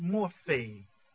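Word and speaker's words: morphe (μορφή): That form is the morph. It's pronounced morphe.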